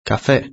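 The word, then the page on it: cafe: Spain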